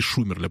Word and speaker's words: Шумерля: Это Мариинский Посад, Канаш и Шумерля.